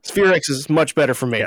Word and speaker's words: SPHEREx: First of all, am I saying that acronym correctly? – Yes, SphereX is the way we pronounce that acronym.